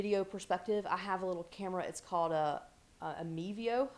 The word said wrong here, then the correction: Mevo: I believe is the way that you pronounce it. It is Mevo, actually. M-E-V-O.